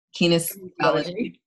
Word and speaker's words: kinesiology: she turned to kinesiology. Am I pronouncing that correctly? – Almost. Kinesiology. – Kinesiology.